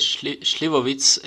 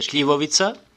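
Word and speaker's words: šljivovica: Ok, eh, dice Slivovitz. es en una pronunciación eh, alemana. Eh, en nuestra lengua se dice shlivovica, uh, eh, eh, ciruela.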